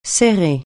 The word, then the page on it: serrer: francais